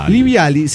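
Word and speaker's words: živjeli: Sí, en realidad se escribe la Z con una casita arriba, una I, una V. Bueno, se pronuncia Liviali. Se pronuncia nada que ver cómo se escribe, ¿eh? – Póngale.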